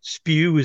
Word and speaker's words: SPBW: So it's quite understandable that when the people who founded the SPBW, or SPEW as it's rather unfortunately pronounced as an acronym in the beer world,